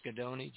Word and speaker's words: Ghidoni: But Steve Gadoni, I think his name is, pronounced Gadoni, G-H-I-D-O-N-I.